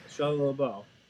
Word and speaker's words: Shia LaBeouf: Shia LaBeouf. I think that's how you say his name. – Oh, yeah. – He did Shia LaBeouf.